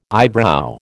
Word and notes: eyebrow: American English

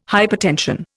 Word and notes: hypertension: Indian

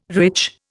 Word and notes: rich: Indian

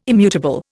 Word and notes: immutable: Indian

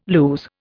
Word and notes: lues: Indian

